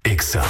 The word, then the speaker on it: Exa: No se dicen éxitos. Se dice Exa. – En todas partes. – En todas partes. Ponte Exa FM 97.3.